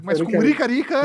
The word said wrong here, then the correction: rica rica: Eu acho que é assim que se pronuncia, na verdade. Se eu tiver errado, por favor, me corrijam. Vocês podem mandar o jeito correto de se pronunciar o nome dessa erva pro nosso WhatsApp. – É Rica Rica.